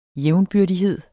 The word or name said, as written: jævnbyrdighed